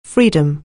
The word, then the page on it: freedom: anglais